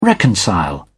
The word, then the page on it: reconcile: British English